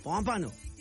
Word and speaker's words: Pompano: Pompano.